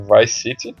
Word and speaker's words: Vice City: Ele morava em Vice City, dessa vez eu falei certo.